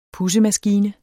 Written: pudsemaskine

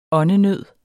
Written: åndenød

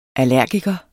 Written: allergiker

